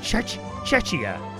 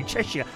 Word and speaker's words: Czechia: And this morning, it's the turn of Chechnya. How do you say it? Chechia. Chechia. C-Z-E-C-H-I-A. Chechia.